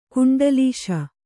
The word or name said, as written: ಕುಂಡಲೀಶ